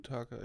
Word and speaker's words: Kiyotaka: Kiyotaka, ja.